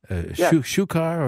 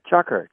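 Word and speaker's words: chukar: It's a type of partridge, shukar or something like that. Is that how you pronounce it? – Chucker. – Oh, it is.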